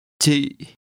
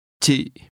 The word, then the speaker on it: Chị: Chị. Chị. – This word for you is pronounced with a low, falling, broken tone.